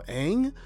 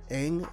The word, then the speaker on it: Eng: They're also losing, I believe it's pronounced Daniel Ang, E-N-G, Ang, led in sacks, by the way, with 4.